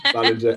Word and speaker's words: Ballinger: Second time. Ballinger. Everyone always gets it wrong. We've pulled all sorts of names over the years. So, you pretty much nailed it second time.